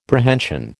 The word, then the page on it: Prehension: American English